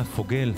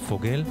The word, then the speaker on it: Fagelle: קוראים לה פוגל, כותבים את שמה F-A-G-E-L-L-E, e, אבל מבטאים את זה פוגל.